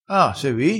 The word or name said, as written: riscappa